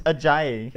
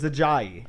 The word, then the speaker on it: Ajayi: First of all, it's pronounced. – It's Ajayi. – It's Ajayi.